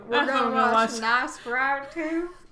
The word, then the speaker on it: Nosferatu: I don't think I'm pronouncing that correctly. – We're going to watch the Nosferatu. Is that what you said? – I'm very sorry. Oh, gosh. Nosferatu. There we go. That was better.